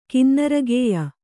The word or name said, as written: ಕಿನ್ನರಗೇಯ